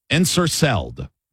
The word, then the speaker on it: ensorcelled: The first one is ensorcelled. Ensorcelled, I believe, is how it's pronounced. Ensorcelled. E-N-S-O-R-C-E-L-L-E-D.